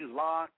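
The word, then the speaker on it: Lak: Gentlemen, that was Locke. Now, Larry, let me ask you a question. Do you like being called Larry, Locke? And I know it's L-A-K, but you pronounce it L-O-C-K, right? – Yes, yes, it's Locke.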